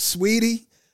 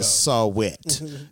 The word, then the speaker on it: Saweetie: It's like, what, Sweetie? Saweetie, the way it's spelled, is like, is this Saweetie? Yeah. I like Sweetie, though. – Saw-wet. – Yeah. – What's Saw-wet talk about? – Right.